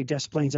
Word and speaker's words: Des Plaines: You wouldn't say Des Plaines, Illinois. They would say, well, that is incorrect. So I remember asking somebody who I considered an expert, why do we pronounce the S's in Des Plaines?